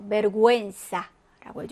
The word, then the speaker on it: vergüenza: 자, 그런데 우 위에 점두 개, dos puntos라고 표현을 하는데, dos puntos가 찍혀 있는 경우에는 그때 이제 우 발음을 해주시면 돼요. 그래서 고의 고의로 돼서, 자, 어떻게 발음할까 하면 될까요? 맞아요. vergüenza라고 해주시면 되고, linguistica라고 읽어주시면 됩니다.